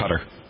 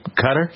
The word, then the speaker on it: Qatar: I always wonder, Qatar, is that how you pronounce it? – Qatar. – You Cutter? – Yeah. – Why'd they spell it that way? – I don't know. – C-U-T-T-E-R instead of Q.